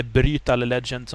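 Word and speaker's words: Brütal Legend: Så det är Brutal Legend, eller Brutal Legend som man, om man ska uttala umlatsen där. Annars så jag har ju sett fram emot Street Fighter 4 och det har ju redan släppts nu då, ja.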